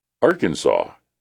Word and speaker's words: Arkansas: And believe it or not, Arkansas took the top spot. Really? A lot of people are still wondering why it's not pronounced like Kansas. Shouldn't it be Arkansas and not Arkansas?